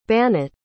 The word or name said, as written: banat